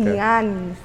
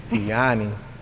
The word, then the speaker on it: Tianis: Say my name right. And I always tell people, you got to say it with a smile. – Okay. – Tianis. If you ain't smiling, you ain't saying it right.